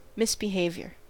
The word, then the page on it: misbehavior: American English